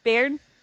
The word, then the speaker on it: Bayern: Bayern? B-A-Y-E-R-N? Bayern? How do you say it? – That's not right.